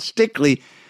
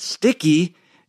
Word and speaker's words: Stickley: Well, not everything, but I know a lot more about Stickley than I did when I was pronouncing it Sticky in the store.